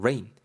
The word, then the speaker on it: rein: R E I N 발음 rain. rain 이렇게 되고요.